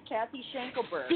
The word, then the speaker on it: Cathy Schenkelberg: You pronounced my name perfectly, Cathy Schenkelberg.